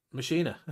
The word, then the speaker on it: Machina: X-Man Mechanic. – Machina. It's Machina.